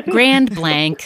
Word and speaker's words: Grand Blanc: Is it Grand Blanc? Grand Blanc? – How do you say it? Grand Blanc, a little bit more American. – Grand Blanc.